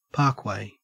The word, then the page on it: parkway: Australian English